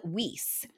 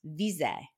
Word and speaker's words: Wiese: Anyway, United Statens, they would call it Weiss, but it's actually pronounced Wiese.